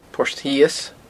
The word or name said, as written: Port Shios